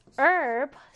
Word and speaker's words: herb: 자, H 발음을 하지 않아요. 자, herb, herb. herbal, 허브의. 자, 미국식 영어에서는 방금 선생님이 얘기했듯이 H를 발음하지 않는다. 하지만 영국식은 발음을 해줍니다.